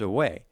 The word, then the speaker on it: aue: So it's just spelt A-U-E, but it's pronounced away.